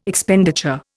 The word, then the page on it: Expenditure: Indian